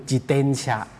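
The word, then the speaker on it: จิ เต น ชะ (自転車): ตามใจชอบอะไรเงี้ยไม่ใช่อย่างนั้นครับที่ญี่ปุ่นนั้นทุกคนมีสิทธิ์เท่ากันแล้วก็ต้องเคารพซึ่งกันและกันครับจะเป็นลักษณะแบบนี้ครับอีกพาหนะหนึ่งที่น่าสนใจนะครับก็คือจิเตนชะ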